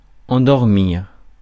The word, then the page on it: endormir: [ɑ̃.dɔʁ.miʁ]